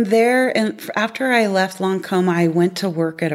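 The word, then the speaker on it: Lancôme: So from Lancome, hopefully I said that right.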